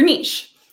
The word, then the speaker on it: niche: And we call this your niche, or niche, you can pronounce it either way.